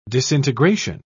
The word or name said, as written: disintegration